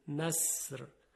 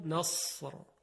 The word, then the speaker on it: نصر: ببینید اگر سین نصر با سین، اگر کلمه نصر با سین بود چگونه میگفتیم؟ نصر. حالا همین رو درشت کنید صدا رو، نصر، نصر. میبینیم که صدای صاد خیلی درشت تلفظ میشه.